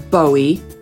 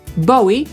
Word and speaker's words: Bowie: No i my jego nazwisko wymawiamy jako Bowie. To znaczy mam taką nadzieję, że tak wymawiacie, bo jest to jeden z poprawnych sposobów wymowy jego nazwiska. Ale można je wymówić jeszcze inaczej. Ciekawe, czy słyszeliście tę drugą wersję. Bowie, przy okazji wam powiem, tak mówią Amerykanie.